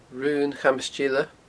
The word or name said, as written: Raon Chamas Teile